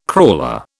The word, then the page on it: crawler: British English